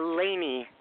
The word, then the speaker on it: Layne: And my name is pronounced Lainey, just the same like Elaine on the Seinfeld show.